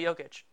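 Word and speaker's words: Jokić: is Nikola Jokic.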